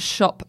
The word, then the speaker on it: Shoppe: Californian style, but it's that sort of – anyway, it's called Shop by Amber Interiors, and Shop is spelled S-H-O-P-P-E.